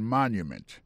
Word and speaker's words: monument: Pour mot monument, il tombe sur premier syllabe là. The Washington Monument. Monument, c'est comme ça on dit monument en anglais.